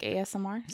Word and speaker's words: ASMR: Is this like ASMR? Is that what you're doing now? Did I say it right? Is it wrong? – You did say it right.